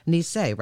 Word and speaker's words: Nisei: It's pronounced Nisei, right? – Well, Nisei means second generation Japanese American.